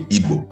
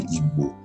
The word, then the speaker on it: Igbo: You know, people say Igbo. Igbo is actually Igbo. You know, so that's actually correct.